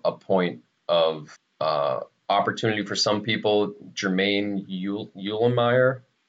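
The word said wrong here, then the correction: Eluemunor: is... – Illuminor. – Illuminor. Is that how you pronounce it? – I think so.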